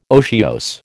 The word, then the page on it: otiose: American English